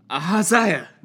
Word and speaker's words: Ahaziah: Yeah, it's best just to pronounce this like a sneeze. – Ahaziah!